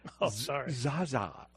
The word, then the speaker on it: Zsa Zsa: It's Zsa Zsa. You said Zsa Zsa. Well, I don't know what they... Like, this is a major... – It's Z-S-A.